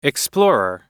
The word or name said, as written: explorer